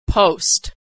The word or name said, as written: post